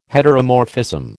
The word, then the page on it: heteromorphism: American English